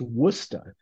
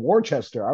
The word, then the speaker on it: Worcester: So you always have people saying Worcester. I want to go to Worcester. – Yeah. – And it's pronounced Woosta. And my siblings lost it when I told them that I had seen that shirt in the middle of Ghana, you know.